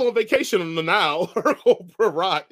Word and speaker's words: Poirot: Hercule Poirot.